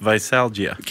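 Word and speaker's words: veisalgia: Visalgia? – Yeah. – Is that the correct pronunciation? – That's how I took it. – That's the medical medical name for hangover is visalgia.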